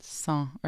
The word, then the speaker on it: Sans: How do you pronounce it? Sans? – Sans or sans. – Sans. And it's French? – It's French.